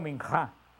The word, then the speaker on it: minjá: Este sacrificio de oblación o de ofrenda se pronuncia en hebreo minjá, el sacrificio de minjá.